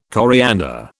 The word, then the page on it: coriander: British English